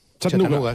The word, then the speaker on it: Chattanooga: ¿Y se pronuncia así en Chattanooga? – Chattanooga.